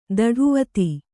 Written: ದಢುವತಿ

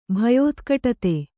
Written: ಭಯೋತ್ಕಟತೆ

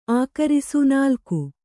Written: ಆಕರಿಸು

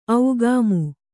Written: ಔಗಾಮು

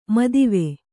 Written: ಮೋದವಿ

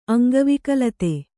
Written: ಅಂಗವಿಕಲತೆ

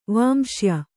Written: ವಾಂಶ್ಯ

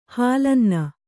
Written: ಹಾಲನ್ನ

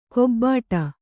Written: ಕೊಬ್ಬಾಟ

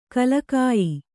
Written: ಗೆಲುಗಯ್ಯ

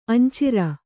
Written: ಅಂಚಿರ

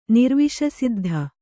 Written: ನಿರ್ವಿಷಸಿದ್ಧ

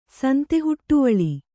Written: ಸಂತೆಹುಟ್ಟುವಳಿ

